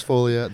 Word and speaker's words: Foglia: That's Carlos Folia. The G is silent. It's F-O-G-L-I-A.